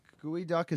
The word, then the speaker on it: geoduck: It was gooey duck. Gooey duck is a mollusk. – Oh, that shit's gross. – It's actually pronounced or spelled yeah. geoduck.